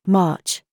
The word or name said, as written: PALM